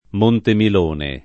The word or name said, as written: Montemilone